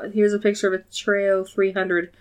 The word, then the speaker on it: Treo: But the phone is a, I think it's called, pronounced Treo. It's T-R-E-O.